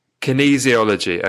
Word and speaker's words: kinesiology: The Witten method, did it originate from, is it Keynes? How'd you say it? Kinesiology or something? I've butchered that. – Kinesiology.